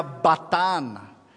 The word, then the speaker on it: Basán: Basán, en el idioma antiguo de la región, Ugarit, se pronuncia Batán.